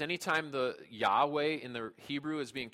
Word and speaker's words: Yahweh: So in the original Hebrew language, this name appears as four consonants, YHWH, to which we add vowels in between and pronounce Yahweh.